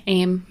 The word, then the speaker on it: AEM: So if we have previously talked a little bit about assistive technology, but we've used the word AIM now, but there's AEM and AIM. And they're both pronounced the same way. – AIM.